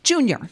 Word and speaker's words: JNYR: And performing in our live studio sessions today, we have Toronto R&B artist, who only Kate could pronounce right, Junior. – Junior. It's JNYR, but it's Junior.